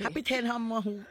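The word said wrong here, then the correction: අපි හෙට හම්බවෙමු: did I say it right no yeah api heta hambemu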